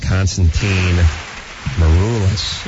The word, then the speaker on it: Constantine Maroulis: I want to thank Constantine Maroulis.